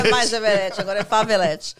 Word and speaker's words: Everett: Na, na, verdade, a pronúncia é Everett, né? Everett. – Daí os brasileiros falam Everett.